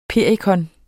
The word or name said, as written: Hypericum